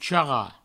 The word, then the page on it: charrar: charra; provençal